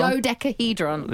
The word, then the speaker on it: dodecahedron: It's not exactly clear what it is, but it seems to be that there are rules of things that you can't say, like decadahedron. I think I've said that wrong. Decahedron? – Dodecahedron? – Dodecahedron. – Yeah.